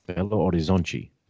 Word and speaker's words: Belo Horizonte: Belo Horizonte, did I say that correctly? – Yeah, you said that right, yeah.